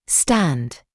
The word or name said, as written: stand